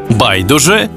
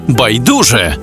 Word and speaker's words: байдуже: Ось 5 українських слів із подвійним наголосом. Байдуже, байдуже, завжди та завжди, мабуть та мабуть, також і також, помилка і помилка.